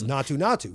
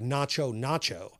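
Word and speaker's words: Naatu Naatu: They pronounce it as Natu Natu, which I guess means something along the lines of rural or country. In the Hindi, it comes out more like Nacho Nacho.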